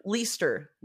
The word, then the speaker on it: Leicester: It's Leicester.